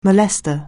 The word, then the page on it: molester: anglais